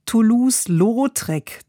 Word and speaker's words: Toulouse-Lautrec: Der Titel seines Kochbuchs Dirty Bistro geht auf ein gleichnamiges Pop-up-Konzept in seinem Kreuzberger Restaurant Toulouse-Lautrec zurück, so spricht man das tatsächlich aus.